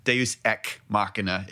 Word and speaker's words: Deus Ex Machina: However, Deus Ex Machina is the correct way to pronounce it. However, Uncle Grandpa pronounced it in the final episode. That's another literary thing. Pronounces it Deus Ex Machina, so I'm going with him.